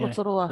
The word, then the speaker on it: Rotorua: It's a hard one to pronounce for me. – Rotorua. – The double R.